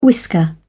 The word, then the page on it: whisker: British English